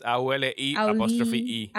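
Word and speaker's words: Auli I (Auliʻi): Auli y así como se pronuncia ese nombre, es A U L I I. Auli I.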